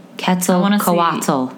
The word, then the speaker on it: Quetzalcoatl: Quetzalcoatl. That's how it's said.